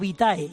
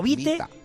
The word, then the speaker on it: vitae: Currículum vitae, cuidado esto, porque las personas dicen currículum vitae o vitae, y es el acento es en la i, es vitae o vite, da igual, exactamente, Goyo.